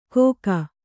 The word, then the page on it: ಘೋಕ: Kannada